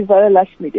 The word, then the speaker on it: Varalakshmi: How do I say it again? – It's Happy Varalakshmi Day.